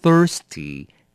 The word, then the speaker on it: thirsty: thirsty 要小心了，这个字的发音 thirsty 的 th 要把舌头伸出来，要放在两上下两排牙齿的中间，念成 thirsty。第九个 water, 名词水，这个大家很常见了。